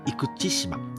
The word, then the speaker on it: 生口島: で後にその広島県の生口島生口、えーと、生の口の島と書いて生口と読むんですね生口島に定住して平山っていうあの姓を名乗っていたらしいんですね。